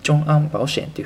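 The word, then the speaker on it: 众安保险: 主演はですね、ジョン・アンというふうに発音します。えー、保険はですね、バオシエンというふうに発音します。えー、って、えー、主演保険はジョン・アン・バオシエンというふうに言いますので、え今日はですね、えこちらの中国語というふうにさせていただきます。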